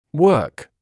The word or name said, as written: work